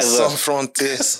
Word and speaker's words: Sans frontières: Sans frontières.